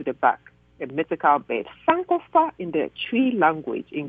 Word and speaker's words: Sankofa: Or Sankofa, okay? Sankofa, that's how you pronounce it.